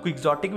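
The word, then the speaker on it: quixotic: वहाँ पे इसका प्रोनाउंसिएशन डॉन की होती है आई गेस और यहाँ पे हम लोग क्विक्जॉटिक भी बोलते हैं तो दोनों प्रोनाउंसिएशन चलता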